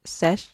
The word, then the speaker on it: Sech: and then there is, I don't know how you say his name, but it's spelled S-E-C-H. Do you say Sech? Sech? Sech. Yeah.